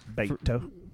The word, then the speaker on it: Beto: Beto.